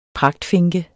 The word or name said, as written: pragtfinke